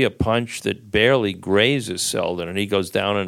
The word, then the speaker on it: Seldon: And the other one was Bruce Seldon, who Mike, the ultimate indignity, which he didn't do intentionally, mispronounces his name as Bruce Sheldon. But Bruce Seldon,